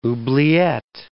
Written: oubliette

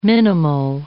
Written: minimal

